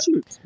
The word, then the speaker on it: chutes: Wait, I think it's spelled C-H-U-T-E-S. – C-H? There's no S in the beginning? – No, S. No. – Oh, like a shoot. Yeah, yeah. That, like a that, shoot. Right.